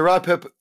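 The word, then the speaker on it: Ruapehu: So there is the Raupehu draft. I hope I've pronounced that That's right. There's the Raupehu draft and the Wakatipu draft.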